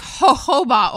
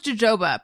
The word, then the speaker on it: jojoba: I always thought it was jojoba, but... I think I'm saying it wrong.